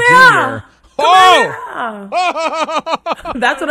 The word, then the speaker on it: Kamehameha: Kamehameha from Dragon Ball Z.